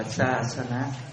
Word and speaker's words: Sasana: Chữ này đọc là Sasana.